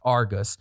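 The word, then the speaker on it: AIRGUS: So AI-RGUS, they dropped the I, it's pronounced Argus, is an AI software plug and play platform that you actually hitch to like your NVR wagon or your cloud NVR wagon.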